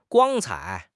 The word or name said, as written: 光彩